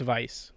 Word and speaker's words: Weiss: It's, well, it's spelled Weiss, pronounced Vice with the new voice work.